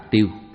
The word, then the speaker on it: tiêu: Chữ sao có âm đọc là tiêu.